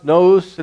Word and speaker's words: nous: This word mind, it's a Greek word. I think it's pronounced nous, and it's talking about a way of thinking, a way of understanding.